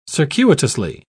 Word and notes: circuitously: British English

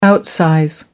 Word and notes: outsize: American English

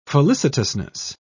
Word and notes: felicitousness: British English

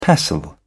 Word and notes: pestle: British English

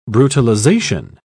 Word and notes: brutalization: British English

